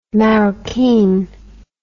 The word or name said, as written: maroquin